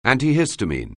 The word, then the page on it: antihistamine: anglais